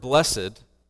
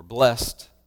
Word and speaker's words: blessed: The word blessed, or blessed, however you want to pronounce it, is a very loaded term.